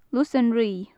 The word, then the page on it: lus an righ: gaelic